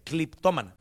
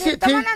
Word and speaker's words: cleptómana: No, pero feo, no se dice cleptómana, se dice cliptómana. – ¿Cliptómana? – Cliptómana. – ¿Es cleptómana? ¿Sí? No, es cleptómana, ¿cómo se dice? Sope. – No. ¿Cómo? Cleptómana. Sí, no.